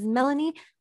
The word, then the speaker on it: Mellanie: My name is Mellanie with two L's because the spelling of your name doesn't change from one week to the next.